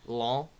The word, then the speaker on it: Lyon: And for early on in that game, it looked like they kind of took the form against Lyon. Is that how you say it? – Yeah.